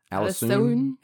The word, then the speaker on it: Alisoun: It's spelled different, but it's Allison. – Uh-huh. – It's spelled like A-L-I-S-O-U-N. Allison. Allison, but I just call her Allison.